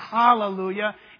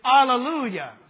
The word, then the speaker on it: Alleluia: Yeah, I know, but that's not how it's said in the original language. It's not Hallelujah. It's Alleluia.